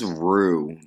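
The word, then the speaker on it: Roux: It's Rue. That's how you pronounce it, Rue.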